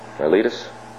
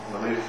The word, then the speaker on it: Miletus: Is that the way it's pronounced, Joe? Miletus? – Miletus is the port of Ephesus, yes.